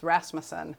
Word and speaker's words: Rasmussen: And I had been there long enough that I knew that my my legal last name was always pronounced incorrectly because it's it's Danish and it's Rasmussen.